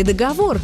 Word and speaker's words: договор: Как все-таки правильно – договор или договор? Договора или договоры? На сегодняшний день ответ звучит так. Строгой литературной норме соответствует второй с ударением на последнюю «о» – договор.